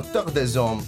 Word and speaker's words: Terre des Hommes: un progetto promosso da Terre des Hommes Italia, visto che è francese. – Bravo con la pronuncia, sei fantastico. E sì